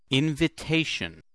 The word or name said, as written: invitation